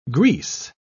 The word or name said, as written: grease